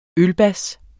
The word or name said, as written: ølbas